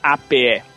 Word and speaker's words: ape: Como é que você pronuncia APE? – Ape. – Exatamente, você não fala app, você fala ape, porque ape. Tem um P só.